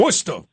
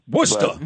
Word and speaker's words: Worcester: And pardon me with my Midwest accent if I'm not pronouncing Worcester correctly. – Worcester! Worcester! – But, there you go. Thank you. Help a brother out.